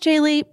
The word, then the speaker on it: Gigli: In our defense, it's spelled G-I-G-L-I. Apparently pronounced Jiggly, but looks like Jiggly.